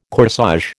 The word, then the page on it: corsage: American English